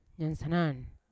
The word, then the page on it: nyansanan: Yawa